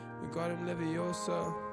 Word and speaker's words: Wingardium Leviosa: Wingardium Leviosa.